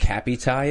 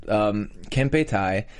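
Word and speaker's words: Kempeitai: I thought it was Cappy Tai at first, and I was like, that doesn't sound Japanese.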